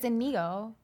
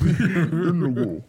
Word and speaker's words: Inigo: I could hear Andre the Giant in my head say his name, and it sounds like Inigo. – Yes. – Inigo.